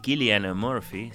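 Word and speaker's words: Cillian Murphy: Tenemos a este irlandés, Emanuel Killian Murphy, según vos me enseñaste a a pronunciar,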